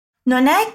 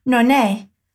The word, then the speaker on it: non è: Veja que a negação non se junta com o verbo é que vem logo depois, que por ser uma vogal faz com que a pronúncia vire praticamente uma palavra só.